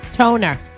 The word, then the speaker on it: Toner: Toner. – Toner? – Huh? We have the right caller on. – Like the copier? – Yeah.